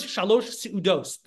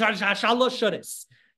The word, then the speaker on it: shalosh seudos: Shalosh seudos, shalosh shuddis. People think it's like two words. Shalosh shuddis. It's shalosh seudos, but it's like kind of a Yiddish pronunciation turned into shalosh shuddis.